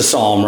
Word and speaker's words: psalm: I mean, it's a psalm, right? Not the psalms, but psalm. Uh, the P is kind of silent. So I don't know if you if you've all talked about that yet, the silent P we we hadn't.